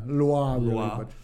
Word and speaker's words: Loa: And as we get into, you know, the rituals themselves, the people who were possessed by the various Loa, am I saying that correctly? – Yeah, Loa.